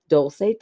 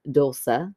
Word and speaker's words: Dulce: And my name is pronounced Dulce, technically. In Spanish, is Dulce. – Okay. – That's the best way I can phonetically spell it out in Spanish, but all my friends pronounce it the first way.